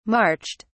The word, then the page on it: marched: British English